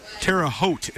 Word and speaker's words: Terre Haute: Terre Haute is how you pronounce it, not Terre Haute.